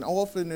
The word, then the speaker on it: often: Well, this time, and we say it often, and often is correct, T is silent, and I'm just going to put that out there.